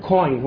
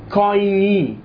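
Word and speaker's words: كويني (κοινή): هي ليست كوين، هو قال كوين جريك، ليست كوين. كوين بالإنجليزي تعني عملة، كويني، كويني جريك بمعنى the common language.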